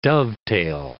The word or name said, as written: dovetail